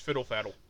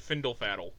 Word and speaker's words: Fiddle Faddle: Oh, and for reference, it's pronounced Fiddle Faddle. – What did I say? – You said Findle Faddle.